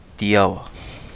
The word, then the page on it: Di Awa: Kapingamarangi